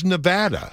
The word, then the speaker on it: Nevada: in the English language, is it pronounced Nevada or Nevada? I wasn't asking about Texan. – No, I'm telling you this is correct. there is no discussion on this point. – Well, it is Nevada.